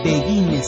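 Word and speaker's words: Biguine: Begin, Begine, Begine se pronuncia.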